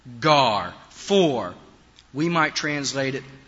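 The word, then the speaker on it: gar (γάρ): That is the word "gar." It's pronounced "gar" in Greek.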